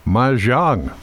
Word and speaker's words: mahjong: We're coming up tomorrow, Mahjong from 1:30 to 3:30. Did I say that right? – That, I think, is pretty close.